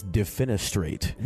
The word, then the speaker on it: defenestrate: Actually, that word is defenestrate. – Wait, is it? What does that mean? – D-E-F-E-N-E.